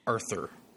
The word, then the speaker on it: Rthur: Those two make up a number of my earliest favorites on FA, plus another artist who goes by the name of R-T-H-U-R, Ruther? – Arthur, I think it's pronounced.